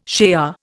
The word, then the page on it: shea: Indian